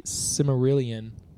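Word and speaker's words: Silmarillion: Something from the Cimmerillion. Is that how I pronounce it? – Yeah.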